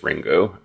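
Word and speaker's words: Ringu (リング): Ringu is just how you would actually like pronounce it in Japanese. Like if you read the characters, it's Ringu.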